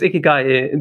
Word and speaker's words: Ikigai: Oh, I'm sorry, most people mispronouncing it. – Yeah, so it's Ikigai.